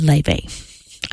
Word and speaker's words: Laufey: Laufey, Leve. I looked it up after I said it wrong, so I apologize to those people who know better than I do.